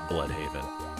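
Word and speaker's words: Blüdhaven: I don't know what else you could do but pronounce it Bloodhaven.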